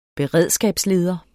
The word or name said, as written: beredskabsleder